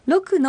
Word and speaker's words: ロック: ロックのクは小さいクですね。 – はい、舌がどこにもついていないで喉が開いた状態で発音してくださいね。